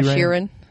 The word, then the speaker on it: Sheeran: Most people know that Ed Sheeran, Sheeran, is that how you pronounce his name? – Yeah, Sheeran.